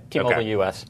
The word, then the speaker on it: T-Mobile US: Yeah, T-Mobile US.